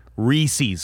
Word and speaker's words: Reese's: But he did say his half-pound Reese's cup. – Yes, and the correct pronunciation. – It has to be a rhyme with the word pieces.